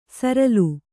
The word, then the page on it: ಸರಲ್: Kannada